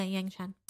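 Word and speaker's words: Yangchen: Yang Cheng, if I pronounced that correctly. – Yeah, Yang Cheng.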